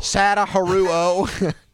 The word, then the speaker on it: Sadaharu Oh: Sadaharu Oh. – Sadaharu Oh.